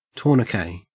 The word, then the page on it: tourniquet: Australian English